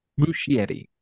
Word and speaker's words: Muschietti: How do you pronounce his last name? Andy Muschietti? Is that how I, you say it? I think that's right. I've been saying Muschietti. Yeah. Muschietti.